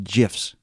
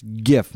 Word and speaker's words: GIF: And then also the second caveat of that is you calling people posting GIFs. – It is pronounced GIF, man. – It's GIF.